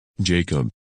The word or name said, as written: Jacob